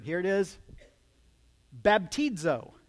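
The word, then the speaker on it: baptizo (βαπτίζω): It's on the first syllable. So, baptizo.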